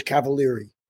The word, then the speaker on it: Cavaliere: Yeah, he pronounced my name correctly, and it screwed everybody up. But the American version is Cavaliere.